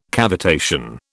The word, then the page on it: cavitation: British English